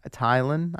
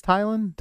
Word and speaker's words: Tylan: Right, it's spelled T-Y-L-A-N. Wallace. – Tylan? – Tylan. I think it's Tylan.